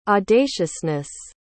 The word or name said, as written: audaciousness